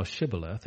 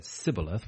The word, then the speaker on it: Shibboleth: Then said they unto him, say now Shibboleth, and he said Sibboleth, for he could not frame to pronounce it right.